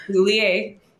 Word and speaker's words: Soulier: Soulier.